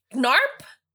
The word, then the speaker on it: Gnarp: Gnarp. It's G-N-A-R-P. Gnarp. – The G is silent.